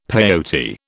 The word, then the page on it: peyote: British English